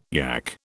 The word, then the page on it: yak: British English